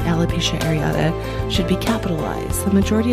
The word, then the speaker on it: alopecia areata: So if anyone is wondering who's listening right now and hasn't quite gotten the pronunciation down yet, it's alopecia areata, or alopecia totalis, or alopecia universalis.